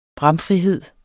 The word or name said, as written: bramfrihed